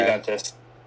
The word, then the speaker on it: Juventus: Juventus. Sorry. Shout out. You guys suck sometimes, pisses me off, but... – I can pronounce Bella Ciao. – Hey, man, you pronounce Juventus without pronouncing the J, so I'm pretty proud of you.